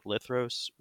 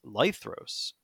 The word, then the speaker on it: Lithros: And actually, when I picked it, I pronounced it as Lithros, but everyone who has ever read it as Lithros out of the gate. And I just basically adopted that pronunciation because it makes a lot more sense.